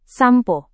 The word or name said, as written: sampo